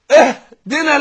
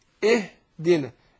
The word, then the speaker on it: اهدنا: كذلك زي ما قال بعض العلماء ان احنا نحذر من الافراط في بيانها، بيان الايه بيان الهاء، قول اهدنا لا، حتى ممكن لو واحد جنبه وطالع وبالغ فيها كده ممكن يخض ويوقع، لا احنا تنطق بالهاء برفق اقول اهدنا اهدنا، لكن ما بالغش فيها